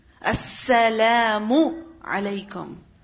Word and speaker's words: As-salamu alaykum: Do not forget to pronounce the seen and the shadda on the seen. As-salamu alaykum.